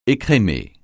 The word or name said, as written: écrémer